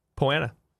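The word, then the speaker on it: Poa annua: Poanua? – Poanua. Well, that's the way it's spelled. Yeah. It's difficult when it's spelled differently than it sounds.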